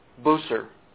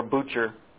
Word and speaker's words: Bucer: Martin Bucer, or Bucher, however you want to pronounce that, B-U-C-E-R.